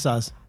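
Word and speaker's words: Saaz: Is it Saz or Saz? – I pronounce Saz.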